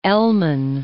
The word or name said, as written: Elman